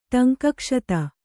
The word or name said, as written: ಟಂಕಕ್ಷತ